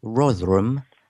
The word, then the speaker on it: Rotherham: It's pronounced Rotherham. It's in Yorkshire, in the north of England, and you're absolutely right.